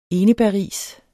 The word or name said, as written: enebærris